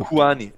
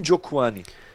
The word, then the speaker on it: Njokuani: Njokuani, Njokuani. Jetzt kann ich es richtig aussprechen. Njokuani.